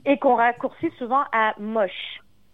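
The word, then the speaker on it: Mosh: Son nom s'écrit M-O-S-H. C'est moche.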